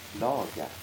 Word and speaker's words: slaga: Använde du ordet slaga om själva redskap? – Slaga. – Så uttalas.